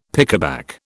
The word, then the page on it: pickaback: British English